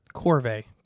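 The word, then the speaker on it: corvina: Corvée. There we go. That's how you say it.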